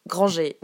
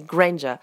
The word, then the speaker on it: Granger: certaines adaptations, par exemple, de jeux vidéo disent Mademoiselle Granger. Non, c'est Granger.